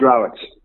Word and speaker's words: Rowett: It's pronounced Rowett.